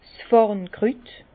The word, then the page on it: 's Fàrnkrüt: Bas-Rhin; Herrlisheim